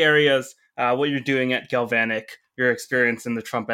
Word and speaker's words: Galvanick: But yeah, if you're happy to, let's dive right into what you're doing at Galvanic.